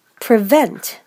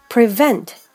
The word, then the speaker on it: prevent: 四つ目、 prevent。 prevent。 PR の発音なんですけども、プーリではなくって、舌を最初から奥に引っ込めておいて、 pre。 pre。最後の t は発音しません。